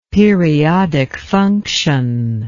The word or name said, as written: periodic function